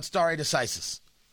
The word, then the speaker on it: stare decisis: Also, let me be clear, you're going to hear a tremendous amount about stare decisis. Stare, which is spelled like stare, and decisis, D-E-C-I-S-I-S, which is the idea, a principle that precedent should determine legal decision-making in a case involving similar facts.